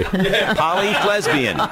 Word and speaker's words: Polyflesbian: Polyflesbian. – I want to make sure I'm saying it right. Polyflesbian, it's like lesbian with an F.